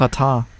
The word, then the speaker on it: Xatar: Ja, aber, da, aber da steht Xatar, was du gerade gesagt hast. Ja, der spricht sich aber Ratar. Ach so, ja. – Ähm, ah, das übrigens, ist kurdisch.